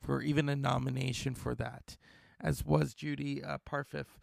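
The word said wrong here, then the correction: Parfitt: Judy Parfit.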